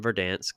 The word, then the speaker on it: Verdansk: The Last Dance in Verdansk, is that how you say it? – Yes.